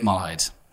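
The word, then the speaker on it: Malahide: In Malide.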